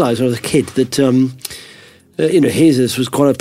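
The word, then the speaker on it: Jesús: And my mother told me that's quite a common name in Spain. It's pronounced Jesus.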